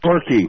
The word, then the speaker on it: Torquay: Torquay.